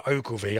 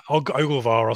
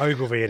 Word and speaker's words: Ogilvy: Yeah, it was Sean Williams who went off, and it was Connor Ogilvy. I was enjoying your pronunciation there. – What did I say? – Ogilvar or something. It was very foreign sounding.